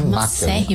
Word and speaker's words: macer: Ou é magríssimo, ou é macérrimo, que magro em latim é máquer. Né? Se escreve macer, isso ele é máquer, né?